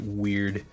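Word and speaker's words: wyrd: It's spelled like W-Y-R-D. I think you pronounce it weird.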